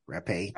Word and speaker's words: Rappe: During the party, a 30-year-old aspiring actress named Virginia Rapp. Did I say that right? Rappe. – Rappe. – Like frappe. Okay.